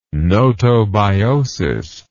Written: gnotobiosis

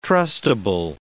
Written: trustable

